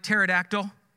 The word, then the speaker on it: pterodactyl: It like pterodactyl? Starts with a silent P?